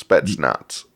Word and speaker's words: Spetsnaz: Well, that's Spatchnosh? Or whatever. Is it Spatchnosh? Am I saying that right? – His thing, it's Spetsnaz. – Spetsnaz.